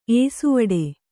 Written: ಏಸುವಡೆ

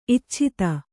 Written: ಇಚ್ಛಿತ್ತ

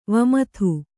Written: ವಿಮದ

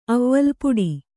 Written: ಅವ್ವಲ್ ಪುಡಿ

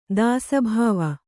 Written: ದಾಸಭಾವ

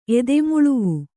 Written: ಎದೆಮುಳುವು

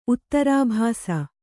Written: ಉತ್ತರಾಭಾಸ

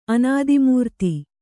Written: ಅನಾದಿಮೂರ್ತಿ

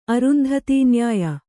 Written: ಅರುಂಧತೀನ್ಯಾಯ

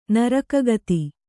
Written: ನರಕಗತಿ

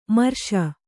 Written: ಮಿರಾಸಿ

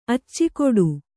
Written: ಅಚ್ಚಿಕೊಡು

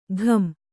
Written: ಘುಂ

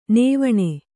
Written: ನೇವಣ

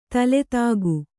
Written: ತಲೆದೂಕ

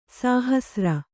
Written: ಸಾಹಸ್ರ

